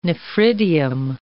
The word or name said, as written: nephridium